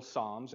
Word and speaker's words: psalm: They are individual psalms, and when you refer to one of the psalms. It is a psalm, okay? So I won't slap you or anything if you say it wrong.